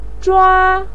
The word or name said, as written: zhua1